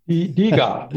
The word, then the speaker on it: DiGA: You will correct me in the German pronunciation of it, the Digo, the Devehe, if I'm pronouncing correctly. – Diga, we, we say Diga. Diga.